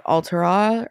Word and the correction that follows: Talia: Talia. – Talia.